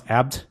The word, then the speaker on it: Abt: And by the way, it's pronounced Abt, apparently. And it is a style of beer.